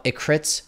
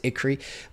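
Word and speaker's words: Écrits: It, by the way, for the folks who saw it, it looks like it's spelled Ecritz. It's Ecree.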